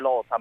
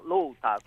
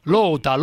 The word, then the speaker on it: lota: Una cosa velocissima, la premessa: non si dice lota ma con la O aperta, ma lota. – Lota, lota.